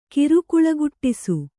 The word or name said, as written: ಕಿರುಕುಳಗುಟ್ಟಿಸು